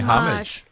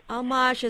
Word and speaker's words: homage: Some people say homage. – Homage is a French word. It's spelled H-O-M-M-A-G-E.